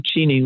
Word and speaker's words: Cheney: But Lynn Cheney, I have to say, or Lynn Cheney, which is how they pronounce it.